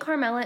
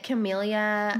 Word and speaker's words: Carmela: You say it exactly the way it's spelled, and for some reason, it always gets messed up at the doctors. I get Camellia. No I get way. Camella. Like, they'll skip the R.